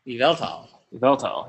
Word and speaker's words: Yveltal: Yveltal. Yeah, so Evel is the emphasis, and then Xerneas. – Xerneas is definitely easier to say. Xerneas. – Yes. – Rolls off the tongue. Yveltal is very.